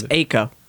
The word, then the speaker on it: Eiko: It's Eiko. – Eiko, yeah. – E-I-K-O.